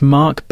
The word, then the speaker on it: Mark: Yes, my name is Mark Baranski. – Could you spell that, please? – Mark, M-A-R-K, Baranski, B-A-R-A-N-S-K-I.